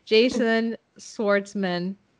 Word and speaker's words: Jason Schwartzman: Jason Schwartzman.